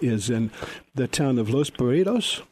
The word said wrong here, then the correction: Los Barrios: I hope I'm pronouncing that right. I never did get it right. It's Los Barrios, right? – Los Barrios, yes.